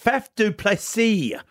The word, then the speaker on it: Faf du Plessis: And I tell you, I like Faf du Plessis.